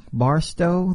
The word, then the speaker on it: Barstow: We got the dude out of Bartow, California. We're going to talk a lot about what the thing, did I say that wrong? Barstow? There you go. Barstow, California.